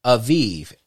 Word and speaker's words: Aviv: In modern Hebrew, it is pronounced Aviv, A V I V.